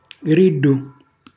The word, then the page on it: grillu: Calabrese